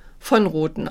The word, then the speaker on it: von Roten: In der Schweiz wird es eigentlich von Roten ausgesprochen, also wird es hm. von betont.